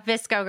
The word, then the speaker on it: VSCO: The Visco Girls.